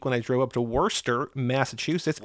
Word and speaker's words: Worcester: So you only know that it's called Worcester if you ever been to Worcester. – For anybody that doesn't know about Massachusetts, it's spelled like Worcester, but it's called Worcester, as in W-O-O-S-T-E-R.